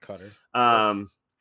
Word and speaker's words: Qatar: Qatar.